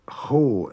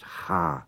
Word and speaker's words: cha: Now, ho is very rarely pronounced like that. It's always pronounced ha, C-H-A.